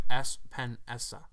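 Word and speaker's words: Epenesa: Is it Espinenza? – Espinessa. Oh, I pronounced it wrong.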